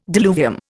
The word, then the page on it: diluvium: Indian